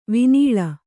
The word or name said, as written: ವಿನೀಳ